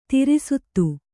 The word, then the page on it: ತ್ರಸ್ತೆ: traste